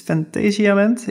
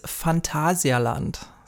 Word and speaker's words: Phantasialand: In English, it's Fantasialand. In German, maybe something else? – Yeah, in German, it is Phantasialand. So Phantasialand.